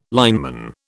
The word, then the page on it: lineman: British English